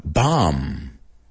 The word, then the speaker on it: bomb: Otra, bomb. Bomba, la B es muda.